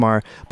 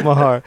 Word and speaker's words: Maher: I do think it's Maher. But I'm thinking of our friend who has a similar last name. That's why I'm pronouncing it. – Yes. Just Bill Maher. – Bill Maher.